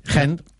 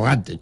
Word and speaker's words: Gent: Óigame, que sí, digo yo, ayer nos desvelaba usted por información de Superdeporte esta anécdota de que el Gent, que ya sabemos que se pronuncia Gent. – Gent o Gante, ¿chan? – No, Gante en castellano viejo, y Gent, si uno quiere hacerse el flamenco en el sentido belga del término, como nos explicó Gauden Villas ayer.